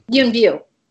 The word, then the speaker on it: Yuen Biao: But Yuen Biao. Is that how you say it? – Yuen Biao.